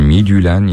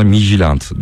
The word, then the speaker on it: Midtjylland: Yapay zekaya sordum, Midyülen ya da Mitülen şeklinde okunuyormuş diyor. Vallahi ilk başlarda herkes buna Mijilant diyordu, tamam mı? Sonra Midyülen oldu, sonra Midyulan diye okundu. Evet, Midyülen herhalde en doğrusu diye düşünüyorum. Mijilant da fena değil yazıldığı gibi.